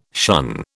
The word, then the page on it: shun: British English